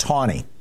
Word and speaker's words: Taney: Except, by the way, his name was pronounced Tawny. There was no W, but that's how they pronounced it.